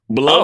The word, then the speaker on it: Blum: And then when I was trying to tell Kat what I was gonna watch, I was just like, I kept saying Bloom and Blum back and forth, and I realized I could probably just look this up. – Pretty sure. – Listen, we can always look up pronunciations. – Well, it's pretty sure it's Blum. – Oh, it is Blum. I just looked on Twitter and. – Blumhouse says it's Blum.